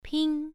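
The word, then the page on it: ping: tone 1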